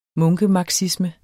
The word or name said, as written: munkemarxisme